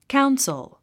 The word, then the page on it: council: káuns ə l; カウンソゥ